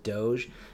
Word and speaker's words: Doge: But they just put an E on the end because he's dog. Dog. Dog. Pronounced Doge. Because a lot of you assholes say Jif.